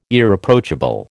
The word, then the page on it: Irreproachable: American English